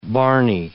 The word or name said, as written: barny